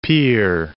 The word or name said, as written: Pierre